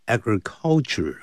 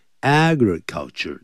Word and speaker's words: agriculture: 在获致成功方面，achieving success, A-C-H-I-E-V-E, 把 E 去掉加 I-N-G, achieving success, 要这么说。还有 agriculture, 不要念成 agriculture, 啊 agriculture, 重音在第一音节。